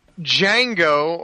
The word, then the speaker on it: Django: I'm sure we left things off, but we are going to start with Django, or is it The Django Unchained? – No, the D is silent.